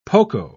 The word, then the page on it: poco: British English